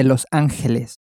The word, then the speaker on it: Los Ángeles: Los Angeles was, of course, a Spanish city, so the name is Spanish and is the same as Spanish, but it's pronounced slightly differently.